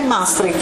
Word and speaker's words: Maastricht: E qui a questo punto allora, e devo pronunciarlo in maniera corretta, dobbiamo ritornare a Maastricht, l'ho pronunciato bene, Maastricht, dobbiamo ritornare a Maastricht.